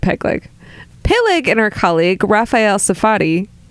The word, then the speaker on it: Peleg: I'm sorry, that's Ortit Pegleg. I'm sorry, you pronounced that wrong.